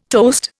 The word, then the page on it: toast: Indian